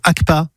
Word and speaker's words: ACPA: Vous êtes la présidente de l'association Les Quatre Patounes, basée à Toulouse, également membre de l'association ACPA à Castres. C'est comme ça qu'on prononce, ACPA? – C'est ça, l'association castraise de la protection des animaux.